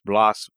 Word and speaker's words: Blas: Tell us about Blas, and we're pronouncing his name in Spanish or in Castilian as Blas Valera.